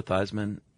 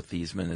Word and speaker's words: Theismann: By the way, you know, Joe Theismann, I don't know if this is true. Supposedly his name was pronounced Theismann until college and he changed it to rhyme with Heisman in a push for the trophy.